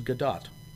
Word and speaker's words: Gadot: Gal Gadot. – Which I just found out she actually pronounces Gadot.